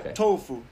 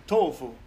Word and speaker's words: Tofo: It's actually T-O-F-O, but they pronounce it Tofu. Tofu.